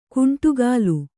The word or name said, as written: ಗುಂಡಕಲ್ಲು